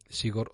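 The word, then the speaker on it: Zigor: Mm, Sigor otra vez con Z, Figor, si escuchas desde fuera se pronuncia ese, pero bueno, se escribe con Z, Figor Aldama, un periodista de referencia, una persona comprometida.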